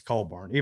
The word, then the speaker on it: Cockburn: I said Cockburn on Fox Bet Live, and I got endless crap for it. And it's Coburn, even though it is spelled Cockburn.